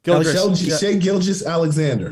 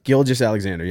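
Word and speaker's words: Shai Gilgeous-Alexander: Is it Gilgeous? – Shea Gilgeous Alexander. – Yep. – Gilgeous Alexander, yeah.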